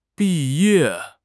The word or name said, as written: bi4 ye4